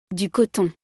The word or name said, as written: Du coton